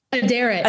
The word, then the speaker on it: Aderet: Aderet.